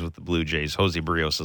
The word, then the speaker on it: Berríos: I'm trying to say it like Morosi says it. Berríos.